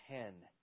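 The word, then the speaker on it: hen (ἕν): Without him became, it's O-U-D-E, and then there's a, it actually has a breathing mark, so it, you would pronounce it as hen, okay?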